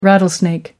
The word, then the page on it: rattlesnake: American English